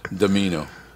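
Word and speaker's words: Dimino: I'm going to start calling him Chris Domino, but he doesn't spell it that way. It's D-I-M-I-N-O. Domino.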